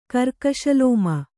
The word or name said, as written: ಕರ್ಕಶಲೋಮ